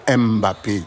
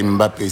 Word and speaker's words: Mbappé: Parce que ici, on se tue à dire chaque fois, ce n'est pas Mbappé, non, c'est Mbappé, c'est une syllabe.